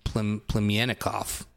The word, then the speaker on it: Plemiannikov: P-L-E-M-I-A-N-N-I-K-O-V. Plemyanikov.